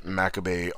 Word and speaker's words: macabre: Because I always pronounce that word wrong, and when I said it, I was like, oh, wait, no, it's Macabre. Right? Macabre? Macabre? Or is it Macabre? – Yes, it's macabre.